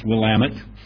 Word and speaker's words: Willamette: But I live in the north end of the Willamette Valley, pronounced Willamette.